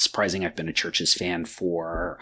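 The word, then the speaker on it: CHVRCHES: I will be joined by one of my favorite musical artists, Lauren Mayberry, the lead singer of Churches. That is Churches spelled with a V, C-H-V-R-C-H-E-S.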